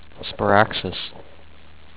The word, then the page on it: Sparaxis: spuh-RAX-iss